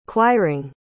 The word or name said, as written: quiring